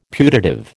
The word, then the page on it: putative: American English